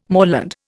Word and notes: moorland: Indian

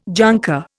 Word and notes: junker: Indian

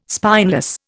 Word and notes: spineless: Indian

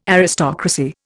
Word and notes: Aristocracy: Indian

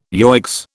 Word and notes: yoicks: British English

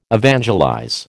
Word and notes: evangelize: American English

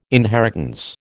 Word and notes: inheritance: American English

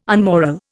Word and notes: unmoral: Indian

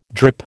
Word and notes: drip: American English

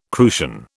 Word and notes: crucian: British English

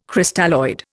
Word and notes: Crystalloid: Indian

